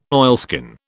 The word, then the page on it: oilskin: British English